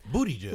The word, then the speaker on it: Buttigieg: Buttigieg. – What if he started just calling him Harvey Milk? Like, what if he started doing that? What if he called him Liberace? Pete Liberace Buttigieg.